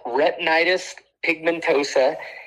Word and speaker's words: retinitis pigmentosa: It's called retinitis pigmentosa, and it's a disease of the retina.